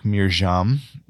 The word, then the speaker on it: Mirjam: And one more little piece of this, you know, there's someone in our family, my wife's family specifically, whose name is Miriam, but the way that it's spelled is with a J because she's coming from a more German speaking country. And so if you think about it, it'd be like Mirjam, but you can hear how Miriam, Mirjam is very similar.